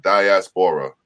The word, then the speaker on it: diaspora: I pronounce diaspora. Yeah, diaspora. Yeah, but the English language sucks. Let me just tell everyone that. – Okay, you get it. – I can't stand it. You know what I mean? It just, like, I knew that's how you pronounce it, right?